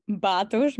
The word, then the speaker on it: Bartusch: I'm Gina Bartusch, my last name, very well pronounced, by the way.